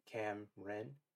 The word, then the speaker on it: Cameron: So, my name is Cameron. It's spelled Cameron, but it's pronounced Cam-Ren.